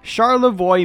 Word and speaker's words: Charlevoix: Charlevoix, Michigan. Spelled Charlevoix, Michigan. You know, Michigan's really shaping up to have a lot of these. How far is it from here? Let me see. That's right. Charlevoix, Michigan is about...